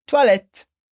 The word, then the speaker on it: toilette: E o mesmo acontece com o S no final de toilette. Outra palavra que eu sei que você já conhecia. Mas agora é sua vez de praticar a pronúncia. Vai lá, repete comigo. Toilette. A gente não fala o S, mas pronuncia o T que vem antes dele.